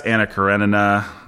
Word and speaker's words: Anna Karenina: Today we are going to discuss Anna Karenina.